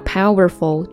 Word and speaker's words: powerful: 最后看到 powerful 这个单词，最后有一个 l。这个 l 是一个舌侧音，在发音的时候呢，要注意把你的舌根往后拉，然后舌尖上抬，可以顶住上齿龈，也可以不顶住，但是舌根一定要后拉。